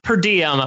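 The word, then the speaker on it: PeriDiam: just go to YouTube and type in P-E-R-I-D-I-A-M. I know it's pronounced Per Diem. It's spelled a little bit differently.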